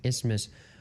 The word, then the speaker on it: Isthmus: Because the TH is silent. – Isthmus.